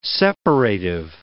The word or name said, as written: separative